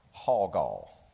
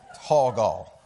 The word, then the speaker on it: hawgaw: That word is hawgaw. H-A-W-G-A-W. And maybe I'm saying it wrong. But in my head, I can hear my dad, who's very southern, going, we're going to go down there and get some hawgaw. It's hawgaw, okay?